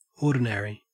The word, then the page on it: ordinary: Australian English